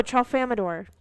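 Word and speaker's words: Tralfamadore: Oh, Trelfamador. Trelfamador. – Okay. – That's what it is.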